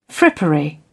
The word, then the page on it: frippery: British English